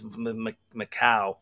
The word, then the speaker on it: Mako: It was Macau. I hope I didn't say his name wrong again. It's funny because at the end of the interview, I was just finishing up and I said, Lindsey Stirling and Macau. I think I said Mako. – Oh, gosh. – Yeah, because it's M-A-K-O.